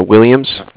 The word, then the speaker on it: Williams: It's spelled C-A-R-D-I-F-F, as in Frank. – C-A-R-D-I-F-F. Your last name, please, sir? – Williams. – W-I-L-L-I-A-M-S.